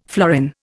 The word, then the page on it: florin: Indian